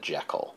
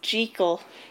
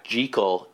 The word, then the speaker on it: Jekyll: It's also worth saying, even though we've been talking this whole episode, that nobody in 1920 probably would have said Jekyll. – Oh yeah, it's Jekyll. – Yeah, Jekyll is the pronunciation from the UK, where Robert Louis Stevenson was from. It's a Scottish last name.